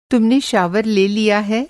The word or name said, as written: शावर